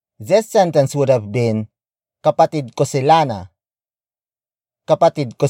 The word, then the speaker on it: kapatid: The next word is kapatid. Kapatid. Kapatid. Kapatid is spelled as K-A-P-A-T-I-D.